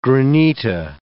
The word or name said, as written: granita